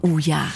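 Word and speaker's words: OOIA: Aber was bedeutet der Name Uja? – Ja, Uja spricht sich ihre Marke, schreibt sich Doppel-O-I-A.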